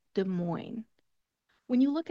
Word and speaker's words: Des Moines: we're going to leave off that S, and instead we're just going to say Des Moines. Des Moines. No S sound at the end.